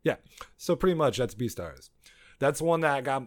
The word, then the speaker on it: Beastars: How do you say the word? Because it's B-E-A-S-T-A-R-S. Beastars? – I call it Beastars.